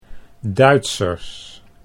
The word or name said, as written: Duitsers